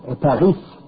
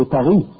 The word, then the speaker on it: Paris: In French also, you don't say Paris. You say Paris. So the S is mute. The ending of the word is mute.